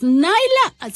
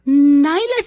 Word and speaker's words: Nyla: Nyla, se escribe Nila con Y. Nyla Stone.